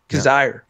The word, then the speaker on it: Kizzire: And that's Doug Ghim and Patton Kaz, Kaz, Kaziri. I can never yeah. say his name. Kizzire.